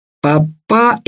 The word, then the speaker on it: папа: «Папа еще нет», — повторил сын Эвминарха, делая ударение в слове «папа» на последнем слоге.